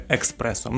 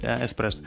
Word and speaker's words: эспрессо: как экспрессо. Многие говорили, а на самом деле правильно говорить эспрессо.